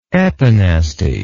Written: epinastic

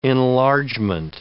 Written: enlargement

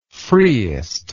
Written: freest

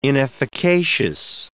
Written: inefficacious

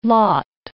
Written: lot

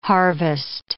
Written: harvest

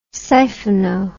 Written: siphono

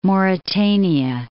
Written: Mauritania

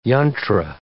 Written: yantra

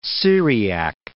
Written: Syriac

